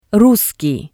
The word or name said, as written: russkii